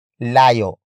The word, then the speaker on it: layo: Malayo is spelled as M-A-L-A-Y-O. It means far. Malayo comes from the root word layo.